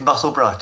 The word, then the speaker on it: Musselburgh: And yes, Musselburgh to rhyme with Edinburgh.